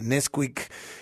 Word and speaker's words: NSQK: Pues eh, ahí está en warpool.la la entrevista con eh, Nesquik que dice El Caminante, el nuevo pop mexicano, se escribe NSQK, Digo varios seguramente por temas generacionales.